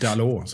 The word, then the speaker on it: Dalot: Och det första jag tänkte på när eh, jag insåg att Dalot skulle få chansen, Dalot sa jag tidigare, jag ber om ursäkt för uttalet, Dalot såklart, silent T där.